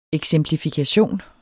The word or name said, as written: eksemplifikation